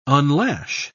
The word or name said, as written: unlash